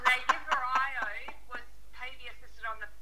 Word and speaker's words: Lagevrio: Lagevrio. – Lagevrio, thank you.